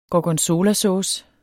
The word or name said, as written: gorgonzolasauce